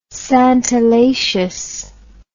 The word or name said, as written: santalaceous